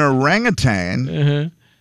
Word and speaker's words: orangutan: An orangutan, orangutan, is that how you say it? Because I always put the G at the end, orangutan.